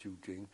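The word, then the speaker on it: Qujing: We spent a night or two, I think, there, and then took the train to Jujing, which, as far as I recollect, was about half day's journey. That's, of course, spelt Kutsing, but pronounced Jujing.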